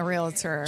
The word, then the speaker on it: Realtor: Two syllables or three syllables? Realtor. Realtor? – Realtor. – Realtor. – Realtor. – Realtor. – Realtor. – Three syllables. – Realtor. – Two syllables. Yeah, two syllables.